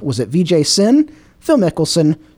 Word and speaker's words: Vijay Singh: Brooks, the correct answer and correct pronunciation, Vijay Singh.